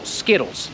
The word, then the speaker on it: Skittles: I po amerykańsku, jak mamy T po środku słowa, bardzo często, albo prawie zawsze zmieniamy to T na D. Dlatego Amerykanin to wymówi Skittles. Skittles. Ktoś w Anglii będzie wymawiać to T, z tego co rozumiem, i może powie na przykład Skittles, albo coś w tym stylu.